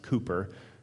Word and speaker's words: Cowper: William Cooper, which actually, if you want to look this up later, it's spelled like Cowper, but it's pronounced Cooper, C-O-W-P-E-R.